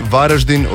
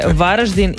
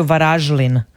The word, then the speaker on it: Varaždin: Pa ne znam, evo Kruno me tu zbunio, kaže, ako vas muči izgovara li se Varaždin ili Varaždin, znajte da neki ljudi izgovaraju Varažlin. Sa L. Varažlin.